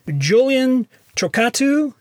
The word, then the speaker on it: Julian Chokkattu: My guest is Julian Chokkattu.